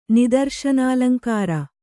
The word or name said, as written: ನಿದರ್ಶನಾಲಂಕಾರ